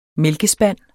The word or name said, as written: mælkespand